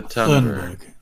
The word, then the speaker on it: Thunberg: Greta uh, Thornburg. That's not how you pronounce her name. How do you pronounce her name? Greta Thunberg.